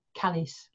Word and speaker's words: Calais: And this is all part of the Tudor lands around Calais, although at the time they called it Calais, like to rhyme with Alice.